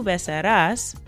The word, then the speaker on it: besarás: So it's basically tú besarás. So that A-S at the end, and there's an accent over that second A to give that emphasis at the last syllable.